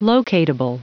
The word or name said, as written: locatable